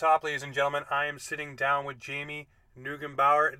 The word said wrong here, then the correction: Neugebauer: It's Neugebauer. No problem, though. – Neugebauer?